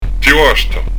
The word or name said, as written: ti basta